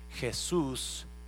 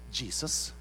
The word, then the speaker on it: Jesús: El problema, como Sophie es de África y no sabe que Jesús no se pronuncia Jesus en español, so le llama Passenger Jesus, please check in for your seat assignment.